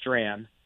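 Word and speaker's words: Strachan: My last name is Stran. It's a Scottish last name with a strange pronunciation, but hopefully somebody out there, hopefully I'll gain one follower at least from that.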